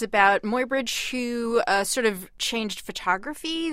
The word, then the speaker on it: Muybridge: Muybridge. Edward Muybridge. – Right, and Edward, E-A-D-W-E-A-R-D, Edward.